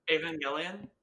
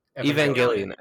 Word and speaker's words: Evangelion: Evangelion? – You guys do Evangelion? Evangelion, okay.